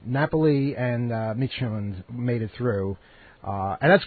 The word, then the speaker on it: Midtjylland: Did I say it right that time? – Midtjylland, yes.